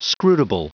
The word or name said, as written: scrutable